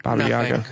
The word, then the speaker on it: Baba Yaga: Baba Yaga.